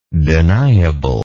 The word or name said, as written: deniablely